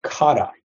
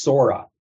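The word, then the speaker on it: kara (空): And when the character stands alone, like when you say, oh, that's an empty glass, it's pronounced kara. This character also can mean spacious and it appears in certain compounds with another character like kukan, which simply means physical space. And there, yeah, it's pronounced ku. This is also intriguingly the character for the sky. And here it's pronounced sora. And so it's interesting in Japanese,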